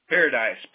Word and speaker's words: Paradise: Paradise. P-A-R-A-D-I-S-E.